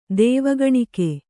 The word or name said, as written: ದೇವಗಣಿಕೆ